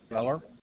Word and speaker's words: Gfeller: James Speller. And that's spelled G-F-E-L-L-E-R.